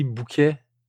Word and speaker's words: Bouquet: All right, so Assault Lily Bucket. I like this. This wasn't bad. – You can pronounce it the French way. It's okay. – Assault Lily Bouquet.